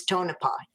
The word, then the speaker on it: Tonopah: Okay, but before I do that, I don't want to forget, you did pronounce Tonopah correctly. It is Tonopah.